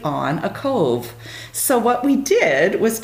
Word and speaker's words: KOV: They say, is it K-O-V or is it Cove? What is it? So it's pronounced Cove.